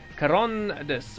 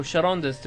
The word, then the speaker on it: Charondas: In ancient Greece, there was a lawmaker called Charondas, or Charondas, depending on how you think about that hard C in the Greek language, who issued a law that anyone who brought weapons into the Athenian assembly would be put to death.